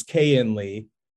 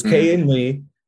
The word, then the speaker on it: K.N. Lee: It's K.N. Lee. It's K.N. Lee, because I've asked him to pronounce it like three different times because I keep getting it mixed up between the cayenne pepper and K.N.